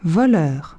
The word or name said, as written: voleur